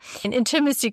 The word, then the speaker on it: Intimacy: Nicole Stuhl ist heute zu Gast und ich habe es gerade ähm, ein bisschen geübt äh, auszusprechen, weil die Betonung ist, habe ich gerade gelernt, auf der ersten Silbe. Intimacy Coach, genau.